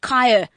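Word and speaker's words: Khaya: Kaya.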